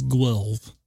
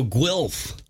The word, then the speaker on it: Guelph: And so just in case everyone was wanting to know how to pronounce that, it's Guelph. – Oh, Guelph.